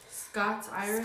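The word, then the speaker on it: Scots-Irish: But he said that they pronounce it Scots, like S-C-O-T-S hyphen Irish. – Scots Irish? – Scots Irish.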